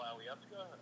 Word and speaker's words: Alyeska: I went up to when I stayed up in Girdwood, and I stayed at Hotel Alyeska.